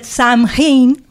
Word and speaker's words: Samhain: Com se pronuncia? – Crec que es diu Samhain. – No se pronuncia. – No. – T'ho hauria d'escriure, però. – Samhain. – Exacte.